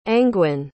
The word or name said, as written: angwyn